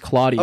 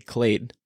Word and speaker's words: Klade: It is technically my last name is pronounced Claudia.